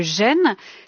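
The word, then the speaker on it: Gêne (仁): qui s'écrit REN mais que l'on prononce gêne, je le prononce bien Rémi Mathieu ? – Tout à fait. – Et qui est un... – Au deuxième ton, ça serait encore mieux. – Dites-le. – Gêne. La voix monte. Gêne.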